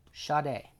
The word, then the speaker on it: Sade: Sade.